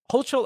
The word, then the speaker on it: Hochul: It's not Holger. Oh my God, bro. Are we in fucking Hogwarts, bro? Holger? What the, dude, it's Governor like. Hochul, all right, Hogo.